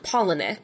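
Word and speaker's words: Palahniuk: So I found a video where he pronounces it, and he says, like, Polinuk.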